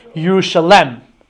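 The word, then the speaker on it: Yerushalem (ירושלם): Actually, in the Aramaic parts of Tanakh, like in Daniel and in Ezra, so when it mentions the city of Yerushalayim, so so the vowelization there is as if the city is pronounced Yerushalem, which I think is probably the source of the English name for the city, Jerusalem, right?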